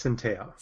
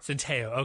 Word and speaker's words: Centeio: And I think Steve Adazio overthought himself by going with Todd – was it Centeio? Is that how you say his name? – I believe it's Centeio. – Centeio, okay.